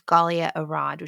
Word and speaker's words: Galia Arad: All my social media, I think, is just my name, which is Galia Arad, which is spelled G-A-L-I-A-A-R-A-D is my last name.